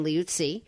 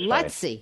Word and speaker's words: Luizzi: It's actually pronounced Lutzi, but just Brian is Lutzi.